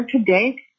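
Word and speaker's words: Kadake: My name is Jennifer Kadaki. If you want me to spell it, it's J-E-N-N-I-F-E-R. K-A-D as in Delta, A-K-E.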